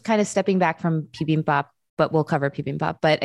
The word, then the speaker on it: bibimbap: Bibimbap? – Bibimbap.